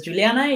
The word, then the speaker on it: Giuliana: Juliana, si es Juliana, es. Eh, sí, todo el mundo tiene un problema con ese nombre, pero es Juliana y me pueden decir you normalmente porque Juliana me siento regañada.